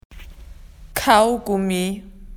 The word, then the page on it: to erase: Australian English